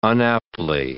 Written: unaptly

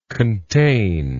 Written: contained

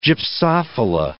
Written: gypsophila

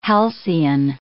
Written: halcyon

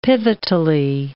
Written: pivotally

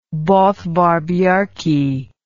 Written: Bodvar Bjarki

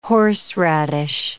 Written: horseradish

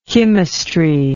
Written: chymist

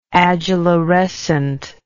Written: adularescent

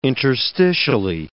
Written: interstitially

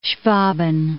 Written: Schwaben

